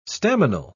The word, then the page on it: staminal: British English